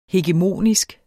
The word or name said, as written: hegemonisk